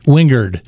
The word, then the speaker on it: Wingard: Wingard. – Yes. With a D.